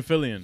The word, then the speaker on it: Fillion: Nathan Fillion. – Fillion. Yeah. – Wait, that sounds mad familiar. Who is that? – Bro, we definitely know him. – He sounds mad familiar. Nathan Fillion.